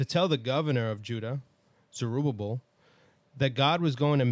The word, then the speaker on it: Zerubbabel: Then we have a prophecy concerning, I never know how to say this name, Zerubbabel, I think is what you're... Zerubbabel.